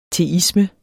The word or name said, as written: teisme